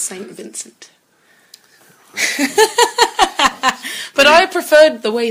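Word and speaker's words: St. Vincent: Saint Vincent. How do you say it? I thought her name was Sam Vincent. – Sam Vincent. – That rhymes. – Saint Vincent, man.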